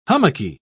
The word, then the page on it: hummocky: British English